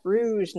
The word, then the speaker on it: Rouge: I'm going to do closing statements, and uh, starting with Sagoni, Moto, and then Chef Rogue. I always say it wrong. Chef Rouge, not Rogue.